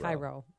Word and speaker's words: Cairo: I mean, if you're not from Illinois, you may not know that Cairo here is pronounced Cairo. – Cairo.